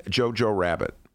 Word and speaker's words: Jojo Rabbit: Have you seen Jojo Rabbit? – No, I've never even heard of it.